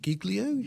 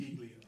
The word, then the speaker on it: Giglio: It's G-I-G-L-I-O. Is it Giglio? – Giglio. – Giglio. Yeah. We might have heard in the background there. He was correcting me, but he was right. It's Giglio.